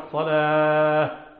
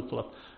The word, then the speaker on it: الصلاة: فالعرب متى وقفت على التاء المربوطة جعلتها هاء. ففي الصلاة والإقامة نقول الصلاة ولا نقول الصلاة، نقول الصلاة ولا نقول الصلاة.